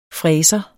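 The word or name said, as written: FRÆSEREN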